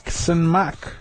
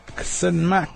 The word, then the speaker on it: ksenmak: I know there's a lot of hard workers out there, so I always say to work in New Hawk is ksenmak. Ksenmak. Ksenmak. And that's to work. Ksenmak.